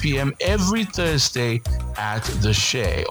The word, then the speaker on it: Che: Of course, Shea is a bar and a grill here in Abuja. It is spelled C-H-E, so make sure you go there for good vibes only.